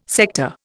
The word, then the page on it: sector: Indian